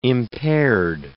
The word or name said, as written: impaired